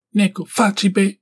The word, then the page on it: Neko fache beh: Galindri